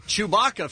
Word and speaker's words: Chewbacca: Chewbacca, for sure, is in my top 10. – Yeah, totally. His name, his name rhymes with caca.